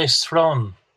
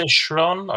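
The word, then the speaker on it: srón: How would you pronounce the word for nose, for instance? – I would say sron. – Yes. – Sron. Would you say shron or do something? – Say shron and equally shrien if we're talking about a limitation of a shron.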